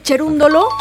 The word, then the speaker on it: Cerúndolo: Oye, Cherúndolo, porque Cerúndolo. Se escribe Cerúndolo. Sí.